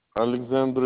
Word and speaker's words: Aleksandr: Alexander Ilkovich.